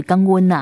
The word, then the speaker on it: 感恩: 就是但是呢他们也是用这个闽南语发音啊，就是刚温呐、啊、哈，就是用这样子一个方式呢去表达他们的感恩。